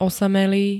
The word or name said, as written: osamelý